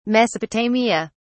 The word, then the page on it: mesopotamia: British English